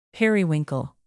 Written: periwinkle

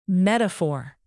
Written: metaphor

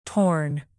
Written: torn

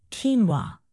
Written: quinoa

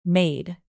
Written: maid

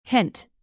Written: hint

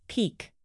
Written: pique

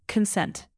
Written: consent

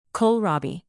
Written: kohlrabi

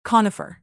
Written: conifer

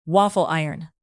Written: waffle iron

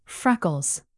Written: freckles

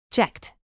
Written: checked

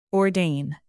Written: ordain

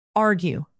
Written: argue

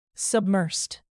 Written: submersed

